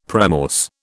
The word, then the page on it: premorse: British English